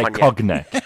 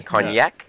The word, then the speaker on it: cognac: He didn't say cognac. What? How do you spell cognac? – C-O-N-G-A-C. Okay. Cognac. – No, C-O-G-N-A-C. – It was unbelievable.